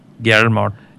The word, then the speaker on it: Guillermo: Guillermo.